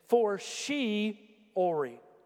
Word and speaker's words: fortiori: Now, the phrase, much more, is what is called a and it's spelled F-O-R-T-I-O-R-I. And here's why I needed my phone to try to give you enunciation. It's for-she-ori, for-she-ori, for-she-ori, for-she-ori. Now, you know what that means?